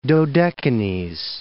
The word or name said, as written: Dodecanese